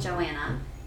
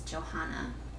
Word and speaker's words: Joanna: The H is silent, right? Or no, you tell me. Not really. So I've grown up being called everything, almost, if you can think about it. So I say my name, Joanna. – Joanna. – Joanna. But if you hear my mom saying my name, she's usually yelling at me, and it's Johanna.